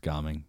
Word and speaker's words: Gaming: Oh, but it's spelled the same way and it's pronounced gomming.